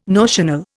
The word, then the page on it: notional: Indian